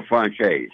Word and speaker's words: Franzese: Is it pronounced properly, Franzese? – Michael Franzese. – Franzese. – Like the dinner? – Like the dinner.